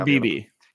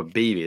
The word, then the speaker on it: Beebe: If you want to just go pure like offensive line guard, I think, whether I'm pronouncing this right, you got Cooper Beebe or Bebe. – Cooper Beebe. – Cooper Beebe. There you go.